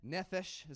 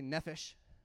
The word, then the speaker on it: nephesh: And in the Hebrew, which is Old Testament, nephesh is how you say that. Everybody say nephesh.